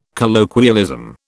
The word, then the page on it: colloquialism: British English